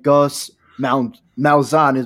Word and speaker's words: Malzahn: But first, here we go with the new head coach of UCF and no other than Gus Malzahn.